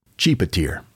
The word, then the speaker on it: Tjipetir: And she started with the word T-J-I-P-E-T-I-R and discovered that there is a village in West Java, Indonesia with that name. And that's how we now know it's pronounced Chepitier.